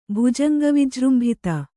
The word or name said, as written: ಭುಜಂಗವಿಜೃಂಭಿತ